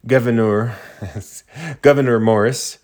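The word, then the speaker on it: Gouverneur: Committee members, Governor Morris, explained, it's spelled like French governor, explained the reasons for the change.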